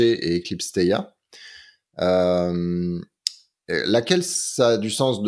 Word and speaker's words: Theia: on le prononce Theia. Que je sache, il n'y a pas vraiment de façon bonne ou mauvaise de le prononcer.